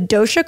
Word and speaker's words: Dosha: You have the Dharma Archetype quiz and then you have the Dosha quiz. Is that how you pronounce that? – Yes.